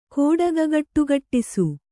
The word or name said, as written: ಕೋಡಗಗಟ್ಟುಗಟ್ಟಿಸು